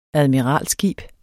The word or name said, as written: admiralskib